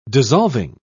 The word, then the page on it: dissolving: British English